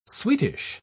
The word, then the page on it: sweetish: British English